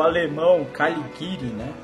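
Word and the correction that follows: Caligiuri: Caliguri. – Caliguri. É, é um I e um U, não é? é Caliguri.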